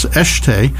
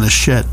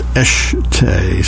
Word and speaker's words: Eschete: It's Eshte, but it's spelled E-S-C-H-E-T-E, and I was pronouncing it Ron Eshet. Because what did I know? Anyway, some listener called me up to chastise me and tell me that I was mispronouncing it. It was pronounced Eshte.